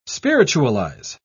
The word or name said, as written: spiritualize